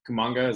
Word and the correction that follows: Kuminga: is that how you say it, Charlie? – Kuminga.